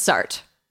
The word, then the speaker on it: Sartre: It's Sartre. – Chart. I heard chart. And Lance Hart, did we say him yet? – No, we didn't. Sartre and Lance Hart.